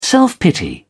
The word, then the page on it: self-pity: British English